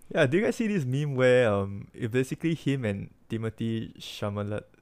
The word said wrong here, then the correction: Chalamet: How do you pronounce his name again? – Chalamet, yeah.